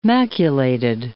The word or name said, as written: maculated